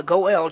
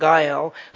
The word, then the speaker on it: Goel: If you kill somebody, there was a person called the Goel, G-O-E-L, sometimes pronounced Gael, who was a kinsman avenger.